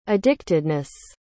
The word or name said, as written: addictedness